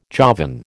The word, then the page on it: Javan: American English